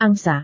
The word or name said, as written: angsa